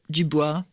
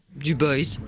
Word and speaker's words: Du Bois: Il s'agit du père du panafricanisme, le sociologue William Edward Burgard Du Bois, Du Bois, si l'on prononce en anglais.